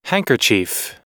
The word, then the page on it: handkerchief: American English